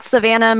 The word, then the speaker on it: Savannah: So my personal Instagram is at Savannah Marie X.